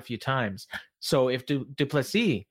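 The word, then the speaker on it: du Plessis: Duplessis, if I'm saying that correctly, Duplessis, Duplessis, almost like uh, Dupree in UFC. It's Duplessis. He that's his pronunciation.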